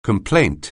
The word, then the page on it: complaint: anglais